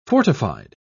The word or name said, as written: fortified